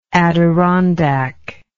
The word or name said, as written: Adirondack